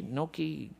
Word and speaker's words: gnocchi: I still remember eating, how do you say it? Gnocchi? Gnocchi? Yeah, thank you.